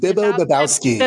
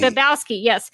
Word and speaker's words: Bobowski: Bibbo Bobowski. Did I say that right? I feel like I didn't say that. – Bibbo Bobowski. – Bobowski, yes.